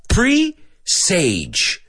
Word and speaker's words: presage: Now, this is an interesting word, porque se escribe pre-sage. Pre, que es pre, and sage, que es salvia, pero no tiene nada que ver con salvia. Ni se pronuncia sage como salvia, se pronuncia sage, presage, okay?